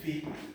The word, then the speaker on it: Fi: Yeah, I know you might ask, but this is Fa, so why am I pronouncing it Fi? We're also going to know why, because we have a sharp sign by it.